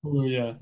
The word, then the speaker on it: Kaluuya: Daniel Kaluuya, I think it's how you say it. Daniel Kaluuya. – Kaluuya, yeah.